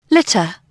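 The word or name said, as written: litter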